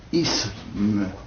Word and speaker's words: isme: La prononciation correcte consiste à prononcer un se, un s, isme.